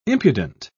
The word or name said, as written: impudent